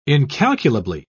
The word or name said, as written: incalculably